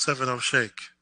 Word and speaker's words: 070 Shake: Well, it's 070 Shake. – Okay. – Like a milkshake.